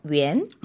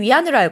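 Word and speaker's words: 元: 보통 우리 중국 돈 단위 하면 웬. – 웬. – 보통 위안으로 알고 있는데 네. 그건 이제 영어식 발음이고 중국어는 웬.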